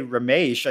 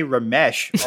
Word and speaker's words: Ramesh: And then almost all the time, actually, in my interior monologue, my last name, I don't say Ramesh, I say Ramesh almost all the time in my interior monologue.